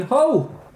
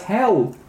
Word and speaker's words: Hull: So, the Bounty was originally the Collier Bethia, built in 1784 at the Blades Shipyard in Hull. Mispronounced by me when I was doing this as Hell.